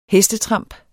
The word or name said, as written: hestetramp